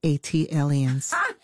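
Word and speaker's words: ATLiens: Tayari, just as Jill did for Philly, as an unofficial ambassador for Atlanta, an A-T-lians. – That is pronounced A-T-aliens. – Thank you. – Like Atlanta alien, A-T-alien.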